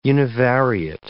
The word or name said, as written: univariate